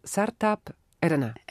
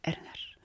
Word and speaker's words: Sertab Erener: Sertab Erener. – Erener.